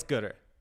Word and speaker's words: goodr: So is it Gooder or are you just saying it wrong? – It's Gooder.